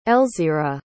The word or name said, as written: elzira